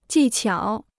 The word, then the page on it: 技巧: jì qiǎo